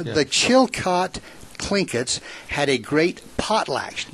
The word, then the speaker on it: Tlingits: The Tlingits. – And it's spelled T-L-I-N-G-I-T-S, but the T is pronounced with a K.